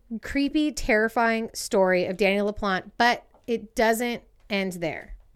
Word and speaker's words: LaPlante: And I'm saying LaPlante. It's spelled L-A-P-L-A-N-T-E. LaPlante? – LaPlante? Yeah.